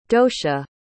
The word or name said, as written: docia